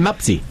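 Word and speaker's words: Mpasi: No va a sonar muy conocido porque la pronunciación es Lionel Mapsi.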